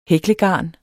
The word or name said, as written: hæklegarn